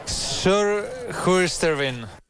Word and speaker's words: Surhuisterveen: Surhuisterveen.